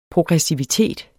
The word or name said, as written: progressivitet